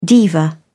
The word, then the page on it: diva: British English